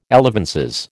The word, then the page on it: elevenses: American English